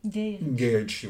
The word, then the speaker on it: Guy Ritchie: On nous dit que ça se prononce Gay. – Gay Ritchie, oui.